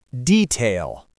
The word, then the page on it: detail: American English